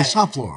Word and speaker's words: St Fleur: Okay, Saint I got Fleur. It. It's really St Fleur.